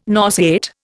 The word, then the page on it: nauseate: Indian